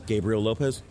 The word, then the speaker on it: Gabriel Lopez: It's Gabriel Lopez.